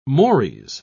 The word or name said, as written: mores